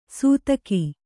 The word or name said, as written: ಸೂತಕಿ